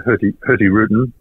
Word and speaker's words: Hurtigruten: But I think having a look through Hurtigruten, normally pronounced without the G, I'm told,